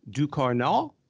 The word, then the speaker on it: Ducournau: Julia Ducournau, and that is the correct pronunciation.